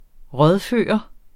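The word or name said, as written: RÅDFØRT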